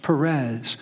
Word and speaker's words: Perez: Sometimes it's spelled P-H in your translations, but it is P. It's pronounced Perez.